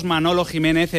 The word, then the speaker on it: Manolo Jiménez: Está ya con nosotros Manolo Jiménez, emblema del Sevilla.